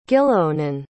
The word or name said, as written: gilleonan